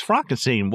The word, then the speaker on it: Frankenstein: Right, yeah, and it's so funny because, you know, he meets him, Dr. Frankenstein, it's Frankenstein, you're putting me on. He's like, no, it's pronounced Frankenstein.